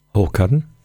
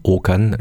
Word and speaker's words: Håkan: le, le, le A avec un petit rond, qui, est le premier A de son, de son prénom, avec un petit rond euh, au-dessus, là, qu'on voit dans les lettres suédoises, ça, se prononce O. Alors, ça, donne quelque chose comme Okan Lindquist.